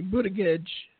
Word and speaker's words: Buttigieg: His name is Boutier. Stephen said it. Boutier. I like it. Boutier. Boutier. – There's a soft G there. I know there's a soft G at the end. – It's actually Buttigieg.